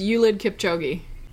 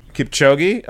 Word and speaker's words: Kipchoge: I always say Eliud Kipchoge. Is that the proper way to say it? – I think it's Eliud Kipchoge. – Kipchoge? Okay. Kipchoge. I call him Kipchoge. We're close.